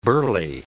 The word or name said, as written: burly